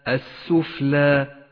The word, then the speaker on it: السفلى: اللام في كلمة السفلى، السفلى، لام شمسية تكتب ولا تلفظ، ولذلك لونت باللون الرمادي إشارة إلى عدم نطقها.